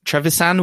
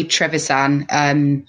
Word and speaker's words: Trevisan: Trevisan, are we saying that correctly? – I've heard Trevisan and Trevisan. So I think it might be Trevisan.